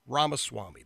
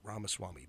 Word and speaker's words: Ramaswamy: Ramaswamy. That's right. Ramaswamy. Vivek Ramaswamy.